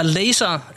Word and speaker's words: Laser: Den er fra Laser. Er det korrekt udtalt, Muff? – Det er helt korrekt udtalt, ja.